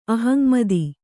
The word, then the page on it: ಅಹಂಮತಿ: kannada